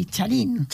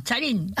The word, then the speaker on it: Charín: És que en tinc tres, Con acento en la I, Charín. – Charín.